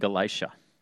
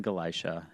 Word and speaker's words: Galatia: Phrygia and Galatia. They went through the region of Phrygia and Galatia, having been forbidden by the Holy Spirit to speak the word in Asia.